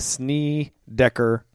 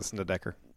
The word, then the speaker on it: Snedeker: Did I say it wrong? The Snedeker. – Snedeker. – Snedeker. – Snedeker. – I thought you were kidding. Snedeker. – I'm sorry. Snedeker. – Not Gosh. Snedeker. – Snedeker.